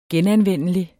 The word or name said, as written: genanvendelig